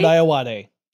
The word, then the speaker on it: Ayoade: But you also like Richard Ayoade. – Yes. – Did I say that correctly? – Richard Ayoade. Yeah. He's the...